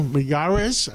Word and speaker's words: Miyares: Well, Attorney General Miyares, I said it right? – Excellent.